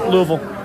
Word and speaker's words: Louisville: It's 2 syllables, Louisville.